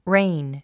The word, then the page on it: reign: réin; レイン